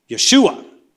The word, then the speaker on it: Yeshua: How did the Jews pronounce that name? Yeshua.